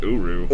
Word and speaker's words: Uru: Was created for Thor out of the Euro metal by the dwarves and infused... – Is that how you say it? I thought it was Uru.